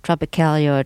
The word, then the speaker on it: Tropicália: Now, you were one of the people who took music in a slightly different direction, something that's been called Tropicália or Tropicalismo. Forgive my pronunciations. – It's perfect now.